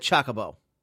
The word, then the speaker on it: Chocobo: Omar says, no, you're saying it wrong. Chocobo. Like hobo.